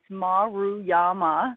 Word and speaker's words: Maruyama: It's I like to tell people to say it two syllables at a time. It's Maruyama.